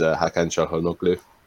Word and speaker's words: Hakan Çalhanoğlu: One of my more like players I fancy a bit more in this league is Hakan Çalhanoğlu.